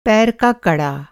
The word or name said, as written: कड़ा